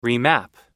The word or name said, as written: remap